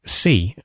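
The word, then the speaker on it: Sí: It should, of course, be pronounced C, as in the Spanish word for yes.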